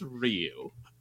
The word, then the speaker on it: Ryu: Uh, his name is pronounced Ryu, and in the movie, they mispronounce it as Ryu because they don't know how to say his name. It's it, it's a person's name. It's Ryu.